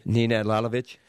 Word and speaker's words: Nenad Lalovic: Nenad Lalovic.